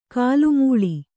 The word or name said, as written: ಕಲುಮಳೆ